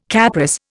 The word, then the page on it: Capris: Indian